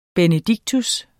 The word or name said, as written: benedictus